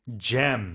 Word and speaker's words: gem: Because gem is a short vowel.